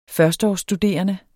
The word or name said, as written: førsteårsstuderende